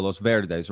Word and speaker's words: Los Verdes: So I sort of want you guys to help us break down, you know, Austin FC and y'all's group as well, Los Verdes, right? Am I saying that right? Which just means the green, right? Just the green, yeah.